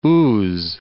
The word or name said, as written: Ouse